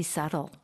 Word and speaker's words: subtle: The word subtle. Is actually subtle. The spelling. – 그렇죠. – S-U-B-T-L-E. There's a B in there. – Subtle 아니군요. – No. We don't pronounce the B. – Right. – It's subtle.